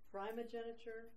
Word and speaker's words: primogeniture: There are two pronunciations. – Yes. – Primogeniture and primogeniture.